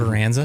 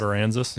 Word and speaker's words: Aransas: And that's how you pronounce that. I've never been able to figure that out. – Port Aransas. – Port Aransas. – Yeah. – Aransas. – Aransas. – I've just been saying around, people be like, yeah, sure.